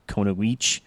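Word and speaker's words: Kunoichi: Very early in the Nick show, Splinter decides, hey, we're going to train you to be a Konoich. – Yeah. – Which is a female ninja. – That's good pronunciation.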